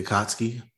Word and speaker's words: Akatsuki: How do you pronounce it? – The Akatsuki. – Is that how you actually pronounce it? – Mm-hmm.